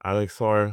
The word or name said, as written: Alex Sarr